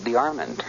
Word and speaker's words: DeArmond: B-O-N-N-E-L-L, which was his mother's maiden name. – And he pronounced his name DeArmond. – Yes. – A lot of people, we've only known him as A.B., DeArmond is the way everyone is pronouncing it, but he pronounced it DeArmond. – Yes, and that's the way I always heard it pronounced.